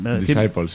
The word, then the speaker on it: Disciples: No sé si lo digo bien, Disciples. – Disciples, sí.